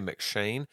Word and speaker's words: M'Cheyne: And the way that's spelled, there was a Scottish pastor, Robert Murray McShane. And so his name, if you're searching for it, it's M apostrophe C-H-E-Y-N-E, okay? And sometimes it's spelled McShane, like McDonald's with the M-C there at the front.